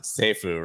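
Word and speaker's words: SAFU: I guess you pronounce it SEFU, right? – That's what I've been saying, yeah.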